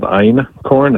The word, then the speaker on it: einkorn: I mean, the major one is called einkorn. It's spelled E-I-N and then K-O-R-N, like iron and then corn.